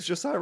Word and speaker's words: Josiah: Well, my name's Josiah Renaudin, as you'd imagine, it's been butchered by so many people, and like I'm usually okay with it until someone's like, right.